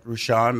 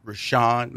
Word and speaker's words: Rushion: It's at Rashawn McDonald. That's right. At Rashawn McDonald. Rashawn is spelled R-U-S-H-I-O-N, last name McDonald.